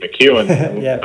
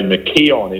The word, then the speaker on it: McKeon: Yeah, I'll just start with one thing that's always funny because my surname's spelt McKeon, K-E-O-N, but everyone pronounces it McKeon unless they hear it.